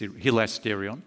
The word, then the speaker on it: hilasterion: It's the word hilasterion. That's how you pronounce that word there. That's hilasterion.